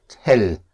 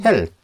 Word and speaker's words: hell: But pronounced hell, not hell. yes, hell.